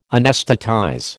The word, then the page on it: anaesthetize: American English